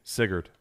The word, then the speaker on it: Sigurd: It's actually woven in that legend of Sigurd. Is that how you say it? – Sigurd. Yeah.